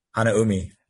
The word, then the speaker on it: Hanaumi: Hanaumi.